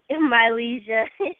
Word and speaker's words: Mylezia: I'm saying your name right? – Mylezia. – Mylezia? – Yeah.